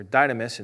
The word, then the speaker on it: dynamis: Dynamic comes from dynamis, or dynamis, and if you want to use the English pronunciation, the word for power.